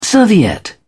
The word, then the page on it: serviette: British English